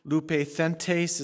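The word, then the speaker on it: Lupethentes (λυπηθέντες): Lupethentes is how you pronounce that Greek word that is listed right there.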